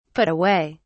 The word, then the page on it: put away: British English